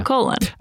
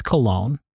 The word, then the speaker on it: Colón: I think people have been very nice in trying to say my name differently so that it doesn't sound like colon. I've heard Cullen. Mm. I've heard Collins. I've heard, it's cologne. – Okay. There we go. – Like perfume.